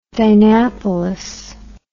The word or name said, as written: dynapolis